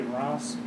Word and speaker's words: Reims: And the Bishop of Reims, if you see it spelled, it looked like it says Reims, but Reims, like that's how you would pronounce it, like rancid. But like the French way, Reims.